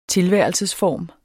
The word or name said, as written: tilværelsesform